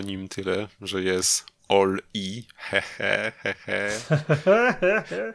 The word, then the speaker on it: Oli: On się nazywa Oli, O-L-I, ale czyta się jak All-I, czyli wszystko w elektryce.